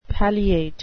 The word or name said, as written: palliate